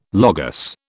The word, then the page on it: Logos: British English